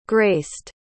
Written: graced

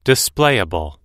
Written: displayable

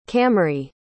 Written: camry

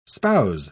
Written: spouse

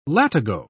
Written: latigo

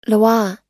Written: loa

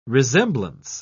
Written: resemblance

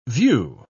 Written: view

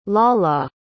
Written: Lala

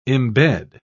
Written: imbed

